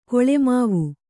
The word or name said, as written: ಗುಲಿಮಾವು